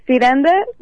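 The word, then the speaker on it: Xiranda: Ah, Ciranda, bueno, nosotros lo pronunciamos con C, o sea, se escribe con X, pero lo pronunciamos con C justo porque viene de una palabra brasileña que se llama Ciranda Cirandiña.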